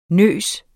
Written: nyse

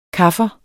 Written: kaffer

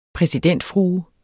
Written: præsidentfrue

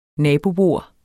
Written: nabobord

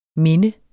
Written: minde

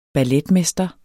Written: balletmester

